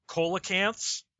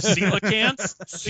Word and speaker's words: coelacanth: you have the deep sea marine biologist saying coelacanths instead of coelacanths? Because yes, I know the word starts with a C, but it's pronounced coelacanth, not coelacanth.